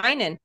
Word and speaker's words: Heinen: Is it Heinen? Is that how I say your last name correctly? – Yeah, Heinen. Yep, you nailed it.